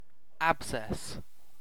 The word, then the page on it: abscess: British English